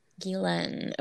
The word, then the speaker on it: Ghislaine: I've heard it said Ghislaine or Ghislaine. – Ghislaine. Okay. – Yeah, the S is silent regardless.